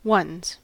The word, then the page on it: ones: American English